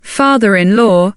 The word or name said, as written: father-in-law